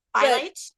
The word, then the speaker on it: phthalates: But the first one is, and I'm just going to spell it, it's phthalates. Phthalates, thank you.